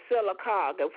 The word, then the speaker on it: Sylacauga: The Talladega County Health Department and the SYLACAUGA site. Then we have What is it? S-Y what now? – It's spelled SYLACAUGA site.